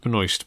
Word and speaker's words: Benoist: That's although, I do like Melissa Benoit, Supergirl, in the TV series. – I think she pronounced it Benoist, but...